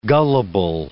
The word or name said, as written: gullible